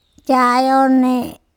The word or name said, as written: caeone